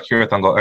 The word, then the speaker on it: Cirith Ungol: Cirith Ungol. Am I saying that right? Yeah. Okay. – Don't ask me. – He's in the Tower of Cirith Ungol.